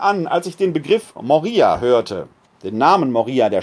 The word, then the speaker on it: Moria: Denn dort gibt es auch ein Moria, wird anders geschrieben und anders ausgesprochen. Die, die, äh, der griechische Ort heißt mit offenem O, mit Omikron geschrieben Moria. Wo ich spreche jetzt von Moria, das ist der Berg.